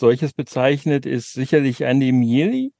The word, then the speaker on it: Miele: Äh, der wird Miele geschrieben wie die Waschmaschine, aber äh, spricht sich, also sein Name, so sagte er uns, ähm, kommt aus dem Italienischen und deswegen äh, ja, wird er eigentlich Mieli genannt.